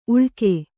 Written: ಉಳ್ಕೆ

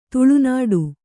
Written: ತಾಳೆ ನೋಡು

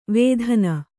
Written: ವೇದನ

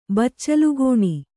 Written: ಬಚ್ಚಲುಗೋಣಿ

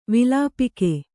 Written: ವಿಲೇಪಿಕೆ

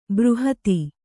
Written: ಬೃಹತ್ತು

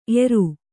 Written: ಏರಿ

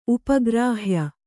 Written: ಉಪಗ್ರಾಹ್ಯ

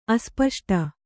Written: ಅಸ್ಪಷ್ಟ

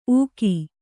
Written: ಉಗಿ

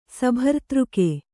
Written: ಸಭರ್ತೃಕೆ

